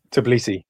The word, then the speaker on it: Tbilisi: Tbilisi. – Tbilisi.